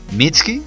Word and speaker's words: Mitski: Y creo que ella, Mitski, ¿sí lo pronuncié bien? – Sí, sí, sí.